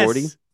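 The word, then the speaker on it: Ribordy: How do we say his last name? – Yes. – Riberty? – I think it's Riberty. – Yes. Riberty. Okay.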